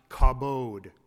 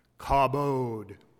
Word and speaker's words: kabod: But that word harkens back to a Hebrew term for glory, kaf bet dalit, usually pronounced kabod. Say it with me, kabod. That's glory in Hebrew.